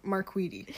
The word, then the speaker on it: Marquette: Wait, is that pronounced Marquette or Marquette? – Yes, yeah, Marquette.